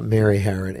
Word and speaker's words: Harron: Mary Herron, H-A-R-R-O-N.